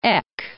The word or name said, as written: Eck